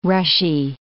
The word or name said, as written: rushy